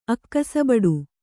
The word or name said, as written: ಅಕ್ಕಸಬಡು